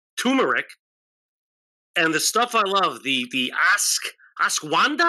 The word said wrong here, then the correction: ashwagandha: Am I saying that right? Yeah, the ashwagandha really?